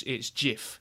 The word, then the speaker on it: GIF: The person who made them says that it's JIF, but I think he doesn't get to decide that because graphics is a hard G, so it's GIF, isn't it? – I'm a GIF guy.